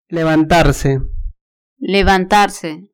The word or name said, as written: levantarse